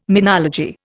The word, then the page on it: menology: Indian